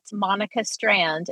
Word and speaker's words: Monika Strand: So if you just follow at Monika Strand, and I can spell that M-O-N-I-K-A.